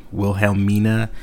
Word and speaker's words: Wilhelmina: It's like Wilhelm I with I-N-A, Wilhelmina. Scharnhorst? Oh man, what a weird name. It's like S-C-H-A-R-N-H-O-R-S-T.